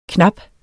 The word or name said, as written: knappe